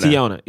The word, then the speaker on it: Teyonah: Is it Tayana? Tiana? Teyonah um, Parris. I say her first name. Uh, who? Oh, Teyonah. – Teyonah.